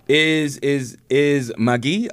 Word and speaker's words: Maggi: You, know, you have to actually say it like that, magi.